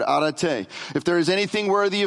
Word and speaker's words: arete: So this word uh, virtue in the, in the Greek, it's arete, arete, and I'm, i actually had that on good authority. I actually called my son yesterday and my daughter. They were together, and both of them confirmed I'm saying it right now.